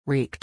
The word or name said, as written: wreaked